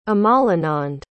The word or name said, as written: amalanand